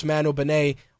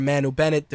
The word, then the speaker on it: Manu Bennett: Of course, Manu Benet, or Manu Bennett, depending on how you pronounce his name, it plays Deathstroke currently on the Arrow TV series, but all signs are pointing towards Joe Manganiello doing the big screen interpretation.